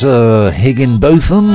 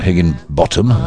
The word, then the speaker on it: Higginbotham: Born Robert Higginbotham. That's pronounced correctly, not Higginbottom.